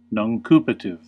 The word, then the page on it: nuncupative: American English